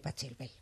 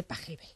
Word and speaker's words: Pachelbel: El otro día me enteré, por ejemplo, aparte de escuchar música, que no se dice Pachelbel, que se dice Pajebel.